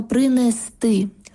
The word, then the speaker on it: принести: Щодо наголосів, то правильно принести, розповісти, занести. Тобто наголос на останній склад «сти».